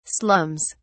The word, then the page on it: slums: British English